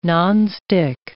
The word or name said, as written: nonstick